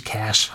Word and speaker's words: Cache: Gut, ist mir bewusst, dass das eigentlich Cash heißt.